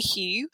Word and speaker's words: Huue: So, I mean, I've asked you here today so that we can talk about your company called Huue. I think I'm pronouncing that right. It's H-W-E, Huue.